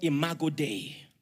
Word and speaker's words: Imago Dei: It is called Imago Dei. And it is spelled I-M-A-G-O, and Dei is spelled D-E-I.